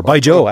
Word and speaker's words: Baijiu: It is Baijiu. Or Baijiu, actually, as it is pronounced.